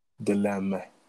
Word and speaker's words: Dilemme: Dilemma. It's spelled Dilemme, but gotcha. It's Dilemma. It's en français.